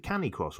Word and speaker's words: canicross: By the way, is it canny cross or canny cross? – Canny cross. Canny, canny cross.